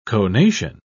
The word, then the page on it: conation: British English